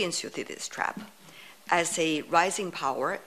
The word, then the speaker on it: Thucydides: The Thucydides trap. I hope I pronounced it well. – You pronounced it exactly right.